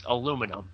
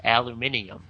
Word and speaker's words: aluminum: That depends a little bit on where you are. In the United States, where I live, it's aluminum. But I think basically the rest of the world pronounces it aluminium.